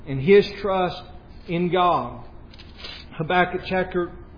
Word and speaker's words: Habakkuk: And I'll tell you what, somebody pronounced that the right way, right? Habakkuk.